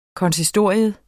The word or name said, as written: konsistorium